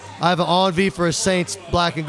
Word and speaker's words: envie: Envy. – Well, they say envy because it's spelled E N V I E, envy, but it's pronounced On V. It's a Cajun French word for a craving, as in I have an On V for hops.